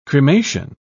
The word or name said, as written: cremation